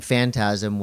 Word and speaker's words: Phantasm: Phantasm? Am I saying it right? – Phantasm.